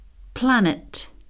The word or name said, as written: planet